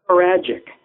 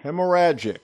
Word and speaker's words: hemorrhagic: Hemorrhagic. – Yeah. – Hemorrhagic? – Hemorrhagic.